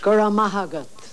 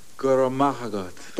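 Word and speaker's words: go raibh maith agat: How do you say thank you in Irish? – Gura mahagat. – Gura mahagat. – There you are. You can say it exactly the way I said it.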